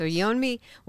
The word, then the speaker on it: Yeonmi: Okay, so again, Yeonmi, did I say it right? – Yes. You said it correctly. Yes. – Okay, yes. – So Yeonmi will be here at Hannibal LaGrange University on Tuesday, April 25th at 6 o'clock.